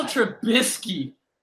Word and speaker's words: Trubisky: Mitchell Trubisky.